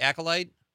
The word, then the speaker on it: Acolyte: Dave says he's going to watch am I saying that right, Conrad? The Acolyte? – Yeah. Yep.